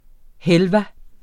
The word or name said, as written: halva